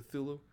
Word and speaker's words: Cthulhu: How do you say that? – Cthulhu? Cthulhu? – Yeah. Cthulhu.